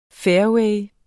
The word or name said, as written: fairway